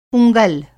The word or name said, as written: उँगल